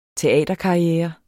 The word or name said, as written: teaterkarriere